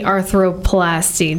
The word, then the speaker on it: arthroplasty: So talk to me about the partial knee arthroplasty.